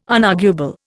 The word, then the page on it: unarguable: Indian